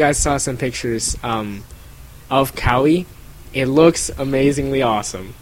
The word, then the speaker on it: Kauai: It's Kauai, yeah.